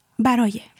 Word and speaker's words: Baraye: Comment on le prononce exactement ? – Baraye.